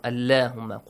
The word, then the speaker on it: Allahumma: And the word Allah, because of this, you pronounce the word Allahumma with a light sound. Allahumma.